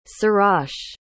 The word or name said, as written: sirach